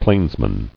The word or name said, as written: Plainsman